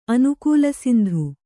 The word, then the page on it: ಅನುಕೂಲಸಿಂಧು: anukūlasindhu